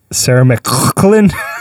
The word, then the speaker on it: McLachlan: It's actually pronounced Sarah McLachlan.